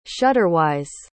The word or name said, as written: shutterwise